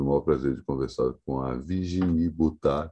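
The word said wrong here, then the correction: Boutaud: também com Botô, melhor dizendo, falei errado o nome dela, sobrenome agora, mas ah, no programa eu falei certinho Virginie, a maioria das pessoas conhece apenas pelo prenome, é a vocalista do Metrô.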